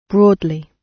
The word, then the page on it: broadly: British English